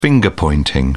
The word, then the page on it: finger-pointing: British English